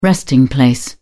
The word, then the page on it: resting place: British English